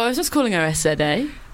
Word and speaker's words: SZA: And it is by SZA, which is just SZA. Which for a long time I was saying it wrong.